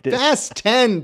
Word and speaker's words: Fast X: It's a little movie called Fast X. – Fast 10, Dave. Don't mispronounce it. – Fast 10, whatever.